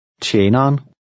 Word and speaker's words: tjeneren: Now repeat number 8 after the speaker, noting the sound of the initial TJ and the contraction of the last two syllables. – Tjeneren.